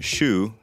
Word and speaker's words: Choux: In French, shoe, that's spelled C H O U X, means cabbage, something we could all use more of.